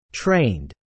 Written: trained

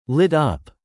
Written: Lit up

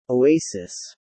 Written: oasis

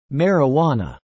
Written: Marijuana

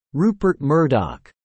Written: Rupert Murdoch

